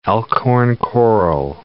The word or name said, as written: elkhorn coral